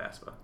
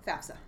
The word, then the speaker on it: FAFSA: So the biggest mistake that was made back when I was a kid was parents just didn't fill out the FAFSA. – Right, FAFSA.